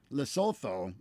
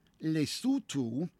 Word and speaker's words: Lesotho: Lesser noted, because it's a much smaller country, but South Africa's landlocked neighbor, Lesotho, or as I believe it is actually pronounced, Lesotho, issued its first licenses for legal cultivation of medical marijuana for export to the global market in places like Canada.